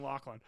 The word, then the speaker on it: Loughlin: I would have remembered if I met Lori Loughlin. – Yeah, I think it would have been. – I would have been like, holy shit, Henry, I've met Lori Loughlin. – I love you saying Loughlin.